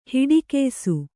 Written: ಹಿಡಿಕೆಯ್ಸು

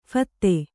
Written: ಫತ್ತೆ

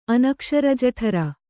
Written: ಅನಕ್ಷರಜಠರ

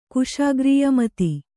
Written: ಕುಶಾಗ್ರೀಯಮತಿ

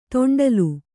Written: ತೊಂಡಲು

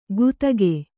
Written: ಗುತ್ತಗೆ

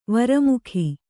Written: ವರ್ಮಿಗ

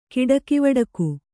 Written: ಕಿಡಕಿವಡಕು

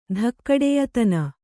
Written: ಧಕ್ಕಡೆಯತನ

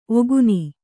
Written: ಒಗುನಿ

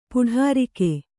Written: ಪೊಡರ್ಕೆ

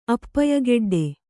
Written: ಅಪ್ಪಯಗೆಡ್ಡೆ

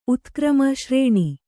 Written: ಶ್ರಾಣ

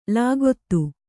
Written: ಲಗತು

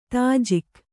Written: ದೋಜಕ್

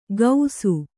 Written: ಗೌಸು

